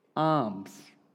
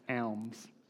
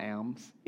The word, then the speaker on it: alms: which last week I pronounced alms. It's not alms, it's alms. So I'm sorry that my Australian accent got in the way and I read the word as it's spelt. Alms.